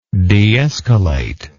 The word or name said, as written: deescalatory